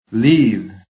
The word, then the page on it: LEAVE: American English